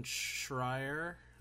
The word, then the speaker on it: Schreier: Yeah, and then I also started reading a book, uh, Blood, Sweat, and Pixels, from uh, Jason Schreier.